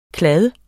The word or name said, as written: kladde